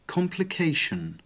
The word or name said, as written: complication